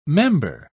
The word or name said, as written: member